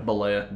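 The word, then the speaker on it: Bollea: Balea.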